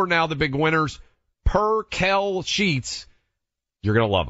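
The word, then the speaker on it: percale: We went back to MyPillow, and we said, hey, let's make sure we get this right. Per-Kel sheets, everyone out there.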